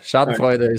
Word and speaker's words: Schadenfreude: Hey, sorry to interrupt, but I've always wanted to know, what is the correct pronunciation of Schadenfreude? How do I say it? – Yeah, you're spot on. It's Schadenfreude. So you're really good. Schadenfreude is perfect.